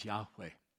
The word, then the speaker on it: Yahweh: I don't care who the people come knocking on your door every Saturday, two by two, tell you his name is not Jehovah. They couldn't have pronounced it. It would have been impossible for them to have simply said the word. His name is Yahweh.